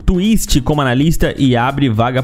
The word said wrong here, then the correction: Twista: Não é Twist, é Twista mesmo. Eu que falei errado, mano. Né? Twista. Twista.